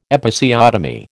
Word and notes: Episiotomy: American English

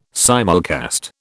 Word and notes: simulcast: British English

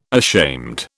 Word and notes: ashamed: British English

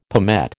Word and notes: palmette: American English

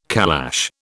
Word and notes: calash: British English

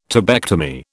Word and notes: tubectomy: British English

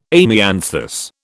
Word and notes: amianthus: British English

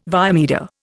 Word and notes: via media: Indian

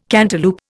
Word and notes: cantaloup: Indian

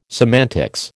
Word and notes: semantics: American English

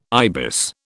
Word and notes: ibis: British English

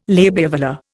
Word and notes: labiovelar: Indian

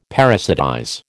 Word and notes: parasitize: American English

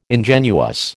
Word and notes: ingenuous: American English

